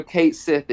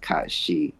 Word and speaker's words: Cait Sith: And also, Cate Sith is actually pronounced Cate Sith. It's not pronounced Kashi, or whatever we thought it was going to be. They've confirmed that it is, in fact, Cate Sith.